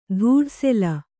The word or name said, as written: ಧೂಢಸೆಲ